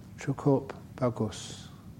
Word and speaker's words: Cukup Bagus: Cukup Bagus. Did I pronounce that right? Yes, oh, that's very kind of you.